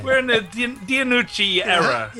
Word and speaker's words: DiNucci: How do you say his last name? – DiNucci. – Yes. – DiNucci.